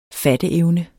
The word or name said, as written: fatteevne